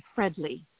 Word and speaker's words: Fredley: Fredley. It's Fred with an L-Y.